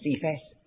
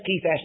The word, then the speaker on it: Cephas: And by the way, don't call him Cephas. Doesn't matter. But it's always a hard C. It should be. And when James, Cephas and John,